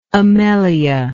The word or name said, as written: amelia